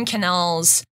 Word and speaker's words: Quesnelle: So that's why you'll meet some Quesnelles around here and in Quebec or just kind of everywhere.